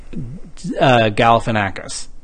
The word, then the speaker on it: Galifianakis: Well, I'm going to have to tell you, my favorite scene in that was the scene with Galifianakis.